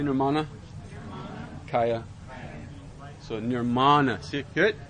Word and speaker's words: Nirmana: That's where that's accented there because of the long syllable there. Nirmana. Kaya, see, like that. So that this is a big guide on how to pronounce Sanskrit. You always accent the long syllable if there is one.